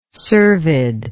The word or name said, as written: cervid